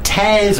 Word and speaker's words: Tes: Is it Tess or Tez or how are we going with the pronunciation on this? Tess. I go for yeah.